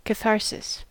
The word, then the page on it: catharsis: American English